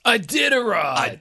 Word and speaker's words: Iditarod: They didn't say it right. It's Iditarod. Iditarod from from dog sled racing, but I had no clue you could do this adventure on a bicycle.